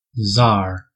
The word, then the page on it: tsar: American English